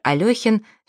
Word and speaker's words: Алехин: Если Василий Аксенов всегда ставил точки над буквой «Ё», то великий шахматист Александр Алехин всегда их зачеркивал, ведь Алехин — неправильное произношение его фамилии.